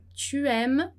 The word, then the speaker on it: tu aimes: Tu aimes, você gosta. Tá? E não pronunciar o S, né? Então, j'aime, tu aimes.